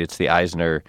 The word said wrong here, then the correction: Isner: Did I pronounce Mahut right? Is that how you say it? – I think you didn't pronounce Isner right. – Isner? – You pronounced Mahut right. – I got Mahut and blew Isner.